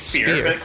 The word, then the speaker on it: Spier: It's Spier.